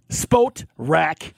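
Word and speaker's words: Spotrac: Well, we were talking about this, Keith, and you know, we've heard various pronunciations on this. It is Spot Rack.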